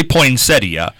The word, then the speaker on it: poinsettia: That's the proper pronunciation, right? – No, it's actually poinsettia.